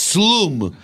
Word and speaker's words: Wslum: It's not the slumlord, it's Slum. – Slumlord. – W-S-L-U-M. – Yeah, well, that's exactly how we pronounce it, Slum.